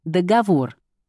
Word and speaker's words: договор: Договор или договор. Литературная норма одна. С ударением на третий слог. Договор.